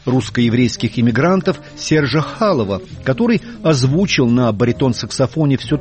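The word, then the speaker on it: Chaloff: Харальда Арлина, настоящее имя, еще один потомок иммигрантов Хаим Арлук, баритон-саксофон Сержа Халова. Его имя и американцы, и французы произносят как Серж Чалов.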